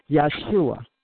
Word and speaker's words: Yeshua: Also, it's Yeshua. – Right. And even that spelled, it's spelled with a J but pronounced with a Y.